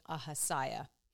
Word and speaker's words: Ahaziah: So I was saying yesterday, Ahaziah. And today I will be saying Ahaziah.